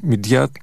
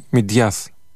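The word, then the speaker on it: Midyat: Κέντρο του οροπεδίου η Μιντιάτ, η πόλη Μιντιάτ, την οποία οι Τούρκοι την προφέρουν Μιντιάτ και οι ντόπιοι κάτοικοι στην αραμαϊκή Μιντιάθ.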